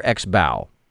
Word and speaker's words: XBOW: Is it Xbo or Xbow? – Xbo.